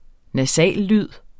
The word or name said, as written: nasallyd